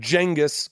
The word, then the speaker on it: Genghis: We don't live in the 1300s where the people in Europe had no idea that China even existed until Genghis, because that's how you pronounce his name, as I came to find out in another podcast called Hardcore History.